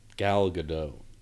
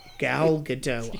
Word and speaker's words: Gal Gadot: So, Jason Momoa and Gail Godet? – Gal Gadot. – Gal Gadot. I butchered the entire fucking thing.